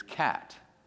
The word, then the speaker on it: qat: The one that Betty and I often use is cat. QAT. Now, if you spell it in the Arabic, it's KHAT.